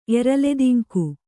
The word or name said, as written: ಎರಲೆದಿಂಕು